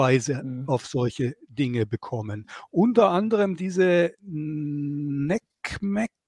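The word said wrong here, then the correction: NCMEC: äh, wie hast du das ausgesprochen? – Nick, Nickmeck, ja. Nickmeck. Nick ja.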